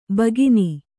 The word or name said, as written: ಬಕ್ಕನೆ